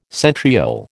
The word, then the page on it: centriole: American English